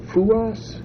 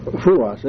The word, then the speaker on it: Fuoss: How do you spell that name? – Fuas. Fuas. F-U-O-S-S.